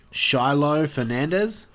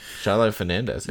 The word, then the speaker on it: Shiloh Fernandez: As her brother, David, a guy by the name of Shiloh Fernandez. – Yep. – Sorry, I thought I was going to butcher that name, but I just fucking... – No, you fucking nailed it.